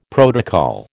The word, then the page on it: protocol: American English